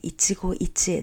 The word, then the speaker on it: 一期一会: で、まあ、これは私がね、まあ、座右の銘としているところなんですけれども、 So that is the word Ichigo Ichie. まあ、それはね、 Ichigo Ichie です。 So、 it's pretty easy to pronounce, so let's practice together.